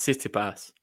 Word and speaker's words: Tsitsipas: Explaining how to pronounce Tsitsipas, and you'll see how he says it. And for some reason, I find it just blows your mind even more because he actually pronounces the T when he says his name.